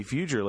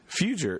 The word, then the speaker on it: Fugere: Hey, thanks for the good pronunciation. A lot of people get that wrong. Even that have known me as long as you have, they Jimmy Fugere, Jimmy whatever, but you nailed it. – Fugere. It's like I say it like it's spelled. So, I even put the E first.